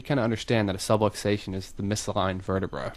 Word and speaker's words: subluxation: We call them the misaligned vertebra that puts pressure on a nerve is called a subluxation. – A subway station. – Rhymes with subway stations. It sounds like that.